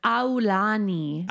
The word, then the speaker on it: Aulani: The thing is in Hawaii, you pronounce all the vowels. So it's Aulani.